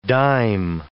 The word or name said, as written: dime